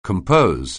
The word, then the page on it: compose: anglais